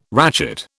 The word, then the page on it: ratchet: British English